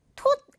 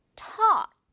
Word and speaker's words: taught: teach의 과거, 가르쳤다가 되는데 이것도 역시 여러분, taught 아니고요. 소심하게 맛있는 떡 아닌 것처럼 조그맣게 벌리지 말고 그리고 taught, taught 아니고 역시 어 발음, taught 이렇게만 해주면 돼요.